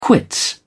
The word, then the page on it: quits: British English